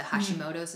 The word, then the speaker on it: Hashimoto's: The Hashimoto's, mm-hmm. is that how it's pronounced? – Yes.